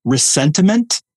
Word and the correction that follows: ressentiment: is that the right way of pronouncing that word? – I think they say ressentiment.